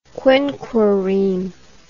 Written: quinquereme

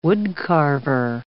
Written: wood-carver

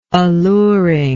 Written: alluringness

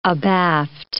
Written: abaft

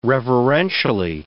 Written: reverentially

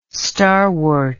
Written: starwort

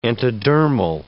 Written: entodermal

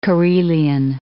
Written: Karelian

